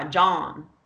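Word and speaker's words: jawn: It's like the word John, Jess. It's J-A-W-N. It's like, oh, that's my John.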